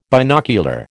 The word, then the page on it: binocular: American English